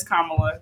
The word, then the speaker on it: Kamala: I thought it's technically pronounced Kamala, but it's Kamala.